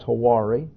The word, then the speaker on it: Hywari: Hawari, H-Y-W-A-R-I is her name, pronounced Hawari, was driving to work headed west on I-64 about 7:30 a.m.